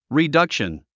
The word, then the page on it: reduction: ridʌ’kʃən; リダクション